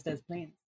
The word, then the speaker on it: Des Plaines: Why, what Illinois, why are you pronounced like that, but Des Plaines is pronounced all of it? – Why Des Moines?